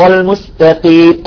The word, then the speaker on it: المستقيم: وسين أيضا يعطي مثال على السين مستقيم، لأن بعضهم يلفظ السين صاد، يقول اهدنا الصراط المستقيم المستى بفخم السين والتاء، لا السين صافية دائما، السين عندما تلفظ السين طالعت صافية. مثلا اهدنا الصراط المستقيم المستقيم، المسجد المسجد، سلطان ما نقول سلطان، ايضا كلمة يسطو يسطو ما نقول يسطو، ايضا يسطو يسطو.